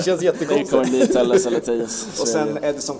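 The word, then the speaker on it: Telles: Nej, Tellis, det, vi, vi, vi, googlade upp det korrekta uttalet.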